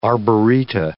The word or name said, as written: arboreta